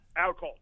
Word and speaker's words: Outcault: How would you pronounce his last name? I've never been sure about how to pronounce his last name. Outcault? – Yeah, Outcault. Yeah, exactly, exactly.